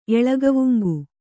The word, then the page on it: ಎಳಗವುಂಗು: Kannada